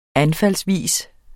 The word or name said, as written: anfaldsvis